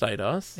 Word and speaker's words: Eidos: It is pronounced Eidos.